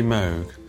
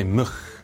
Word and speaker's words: Moog: Well, the Americans say Moog, the Dutch say Moog, and we didn't really know how to pronounce it when the instruments first arrived, so we called them Moogs.